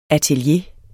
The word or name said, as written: atelier